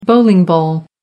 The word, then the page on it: bowling ball: American English